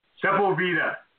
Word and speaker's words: Sepulveda: One was Rosecrans, but it had a much harder time with Sepulveda Boulevard. – Sepulveda. Always called it Sepulveda. Sepulveda Boulevard. Yes.